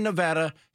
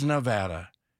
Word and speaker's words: Nevada: Nobody in Nevada says Nevada. Nobody. They all say Nevada. That's how you say Nevada.